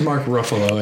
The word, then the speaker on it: Ruffalo: His name is Mark Ruffalo, everyone.